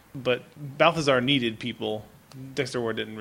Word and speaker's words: Balthazar: And you find out basically that this uncle was enslaved to a demon cat named Balthazar, as they pronounce it. Not Balthazar, but Balthazar.